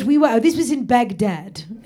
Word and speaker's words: Baghdad: No, it's Baghdad. Baghdad. – Baghdad. – Then, is how the proper pronunciation.